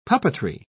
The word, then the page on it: puppetry: British English